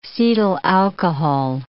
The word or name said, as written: cetyl alcohol